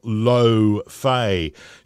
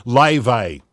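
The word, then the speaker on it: Laufey: She's out of Iceland, so that means, chances are we're saying it wrong. It's actually Leve, she says. She's from Iceland. But then she also goes on to say that, well, we use vowels in Iceland that don't even exist in English, so I'm happy with Leve.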